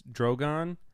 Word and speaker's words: Drogon: It's Drogon. – Okay, Drogon.